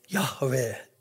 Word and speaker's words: Yahweh: hvordan det skal uttales. Og man sier, Yahweh er det mest sannsynlige.